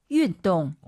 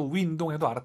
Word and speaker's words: 运动: 运动. – 어렵게 발음하시네요. 그렇죠? 운동. 절대 입을 가로로 win안 했으면 좋겠습니다. 근데 물론 선생님, 가로로 벌려서 윈동해도 알아듣잖아요.